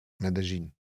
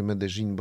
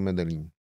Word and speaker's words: Medellín: Medellín. Tak mówię Medellín, bo tak mnie nauczono tam, że tak się to wymawia. Mm-hmm. My tą nazwę bardziej znamy jako Medellín.